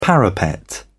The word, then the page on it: parapet: British English